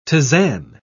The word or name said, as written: tisane